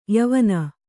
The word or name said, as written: ಯವನಿ